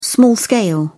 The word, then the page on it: small-scale: British English